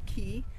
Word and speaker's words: ki: Well, key is your energy, like chi. It would be the Chinese pronunciation, tai chi, people know. – Uh-huh. – Ki is the Japanese pronunciation.